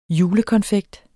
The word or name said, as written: julekonfekt